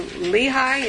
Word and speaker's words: Lehi: When he came to Lehi, is that how you pronounce that? – Yes.